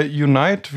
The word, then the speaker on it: Unite: Wir haben ja letzte Woche über die Shopify Unite, wie, wie, sagt, wie spricht man das aus? Unite? Passt, oder? Gesprochen.